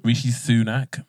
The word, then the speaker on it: Rishi Sunak: Rishi Sunak.